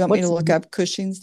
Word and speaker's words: Cushing's: Cushing's disease. – Cushing's. Like pushing, only Cushing? – Uh-huh.